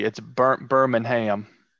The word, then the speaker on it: Birmingham: Is it Birmingham? Is that how they say it? – Birmingham. – There's no G. It's Birmingham.